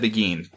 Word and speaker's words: beguine: I've heard begin the beguine before. And I guess they just like, rhymes with sardine.